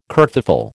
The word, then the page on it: kerfuffle: American English